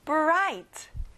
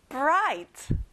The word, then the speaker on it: bright: 밝은, 빛나는, bright. 친구들, GH 발음은 안 나요. bright.